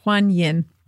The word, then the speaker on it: Quan Yin: That's Huan Yin, goddess of compassion. – How do you spell that? – Q-U-A-N-Y-I-N, Huan Yin.